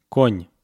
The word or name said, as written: kon